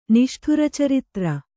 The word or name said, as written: ನಿಷ್ಠುರಚರಿತ್ರ